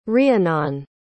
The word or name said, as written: rheanon